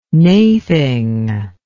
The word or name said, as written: naething